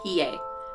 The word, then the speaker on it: Hiei: Hiei.